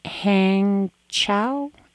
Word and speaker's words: Hangzhou: And you also mentioned Hangzhou. – And that's spelled H-A-N-G-Z as in zebra, H-O-U.